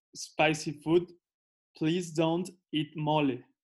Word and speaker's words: mole: How do you say it again? – Mole. – Mole. – Mole. Yeah.